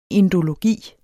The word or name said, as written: indologi